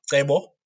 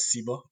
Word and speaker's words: Cebo: So I am Cebo, or you can pronounce it as Cebo in the UK.